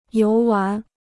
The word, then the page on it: 游玩: yóu wán